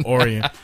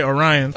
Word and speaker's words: Orion: Orion. That's how they pronounce it. I thought it was Lake Orion.